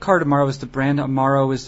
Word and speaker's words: Cardamaro: I also picked up a beverage that is called Cardamaro.